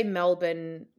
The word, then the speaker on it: Melbourne: Because in America, lots of people say Melbourne, based on the way it's spelled.